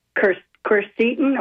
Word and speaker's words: quercetin: And I appreciated your information on the quercetin.